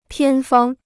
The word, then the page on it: 偏方: piān fāng